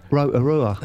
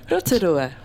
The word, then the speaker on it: Rotorua: How do you say it? – Rotorua. – Is that the correct? – That's the right way to say it, isn't it? – Listen. Rotorua. – Maybe I should do the news. No, Rotorua.